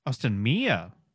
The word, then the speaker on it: Austinmer: Is that how I say it? Austinmer? Austinmere. They should put an E on the end. That would make that easier to pronounce.